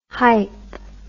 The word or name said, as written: hipe